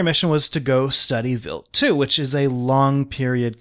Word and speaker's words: Wild 2: It was Stardust flying past Vilt 2, Vilt spelled WILD.